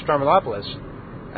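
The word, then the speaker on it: Stroumboulopoulos: And I actually said his name right, didn't I? – Yeah. – Wow, I said his name right. And that's a thing. You can say a name like George Stramanopoulos, and we have George Stephanopoulos in the United States who does commentary, I believe, on ABC.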